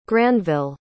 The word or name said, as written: granville